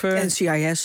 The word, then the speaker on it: NCIS: Of, NCIS zou ik gewoon zeggen.